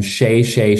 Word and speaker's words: Shay Shay: On Instagram, I am Shay Shay Show. Shay Shay is spelled S-H-A-Y, S-H-A-Y, and then show.